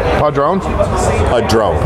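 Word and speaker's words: Padrón: Now, how do you pronounce that? – Padron? – Padron. – Padron. P-A-D-R-O-N. – Just put it out there, Padron.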